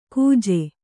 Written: ಕುಜೆ

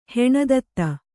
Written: ಹೆಣದತ್ತ

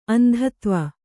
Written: ಅಂಧತ್ವ

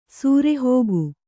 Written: ಸರಿಹೋಗು

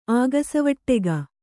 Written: ಆಗಸವಟ್ಟೆಗ

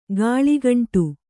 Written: ಕಳ್ಳಕಿಂಡಿ

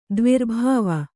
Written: ದ್ವಿರ್ಭಾವ

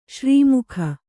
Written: ಶ್ರೀಮುಖ

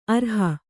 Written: ಅರಿಹ

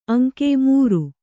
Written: ಅಂಗ

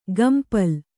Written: ಕಂಪಳ

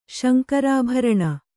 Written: ಶಂಕರಾಭರಣ

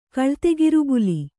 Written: ಕಳ್ತೆಗಿರುಬುಲಿ

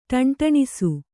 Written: ಢೆಂಢೆಣಿಸು

